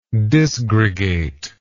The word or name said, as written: disgregate